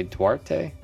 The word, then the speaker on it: Duarte: Here's an actual link, and it's a MechWarrior Living Legends, the novel, and it's by Francisco, I want to say Duarte, is that pronounced right? – Sure. – I probably screwed up. That's Stalker, yeah. – Sure. – Did I get it right, Stalker? Oh, sweet. See, I finally pronounced one damn name right.